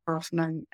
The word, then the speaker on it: Hofmann: So my main website is my name, Mel Hofmann.